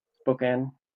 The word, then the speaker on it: Spokane: Nick's out of uh, Spokane. Is that how you pronounce that, Christopher? Spokane, Spokane. – Yes, Spokane. Spokane.